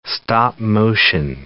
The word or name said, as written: stop-motion